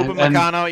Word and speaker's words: Upamecano: I think the only major thing I can think of is up McCann, I can't pronounce his name now. – Upamecano, yeah.